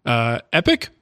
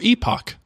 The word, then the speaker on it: epoch: Epic or epoch? What do you prefer? – Epoch. – I like epic.